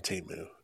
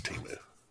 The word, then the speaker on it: Temu: but it's fucking Temu. Everybody knows it's Temu.